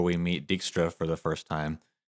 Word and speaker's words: Dijkstra: I hear it get pronounced a couple of different ways sometimes. Okay. I always say Dijkstra. I feel like that's what it's how they say it in the games, and that was my first exposure to him as a character.